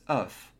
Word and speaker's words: œuf: The pronunciation of egg in the singular form is œuf. Pronounce the final F. In the plural form, the final F and S completely disappear. Hence, to say some eggs, for example, you'd say des œufs.